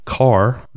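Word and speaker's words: Kaa: Yeah, so Mowgli is actually Mowgli, kind of like, you know, the first part of his name, M-O-W, rhymes with cow, Mow. So Mowgli and not Mowgli. And in addition, Ka the snake was supposed to be Kar the snake, and Baloo the bear should have been Barloo the bear, according to the book.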